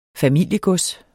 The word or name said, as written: familiegods